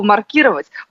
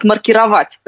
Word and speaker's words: маркировать: У лингвистов есть такой термин, тот самый, который сейчас Есения как раз употребила, и там как раз ударение ставится на букву «и». А вот маркировать, предположим, продукты в холодильнике, вот там уже будет другое ударение. Просто разные смыслы. У нас такое бывает, в зависимости от смысла меняется ударение.